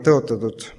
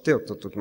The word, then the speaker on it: tõotatud: Eesti keeles on see väga kentsakas sõna, kui natukene valesti hääldada, siis saab sellest tõotatud maast teotatud maa. Nii et proovime ikka mõelda sellest tõotatud maast, mitte teotatud maast.